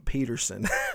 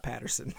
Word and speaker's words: Peterson: His name is Eugene Peterson, not Patterson.